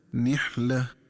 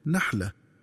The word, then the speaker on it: نحلة: وكذلك ينبغي التنبه للنطق الصحيح لكلمة نحلة، فتقرأ بكسر النون هكذا نحلة، ولا تقرأ بفتح النون هكذا نحلة.